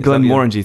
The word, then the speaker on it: Glenmorangie: Glenmorangie, thank you.